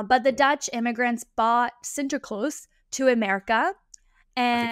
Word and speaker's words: Sinterklaas: Google how to say like the proper name, but I like saying Sinterklaas better. But let's yeah, say it right, Sinterklaas.